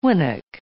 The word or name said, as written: winnock